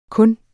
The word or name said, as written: kun